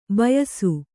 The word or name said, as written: ಬೇಯಿಸು